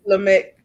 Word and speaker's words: Lamech: Yep, Lamech.